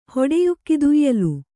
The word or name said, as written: ಹೊಡೆಯುಕ್ಕಿದುಯ್ಯಲು